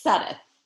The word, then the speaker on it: Sudduth: I got to reach to make sure I'm at least saying did I say your name right, Suddhoo? – It's Suddeth. – Suddeth.